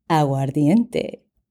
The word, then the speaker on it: Aguardiente: Aguardiente.